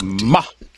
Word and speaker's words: Mahdi: I say Islamic Mahdi, and he's got the correct pronunciation. But anyway, this Mahdi.